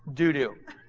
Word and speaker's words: Dodo: And then in 2 Samuel chapter 23 verse 9 he says, and after him was Eleazar, the son of Dodo, a Horite, one of the three mighty men with David. Actually, it's pronounced Dudu. That is the short name for David.